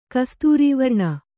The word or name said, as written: ಕಸ್ತೂರೀವರ್ಣ